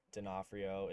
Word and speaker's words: Donofrio: But so my last name is Donofrio. It's pronounced that way. All my handles, though, I just kind of have a play on my last name where my handles are Donafritos, even though that's not how you pronounce my last name.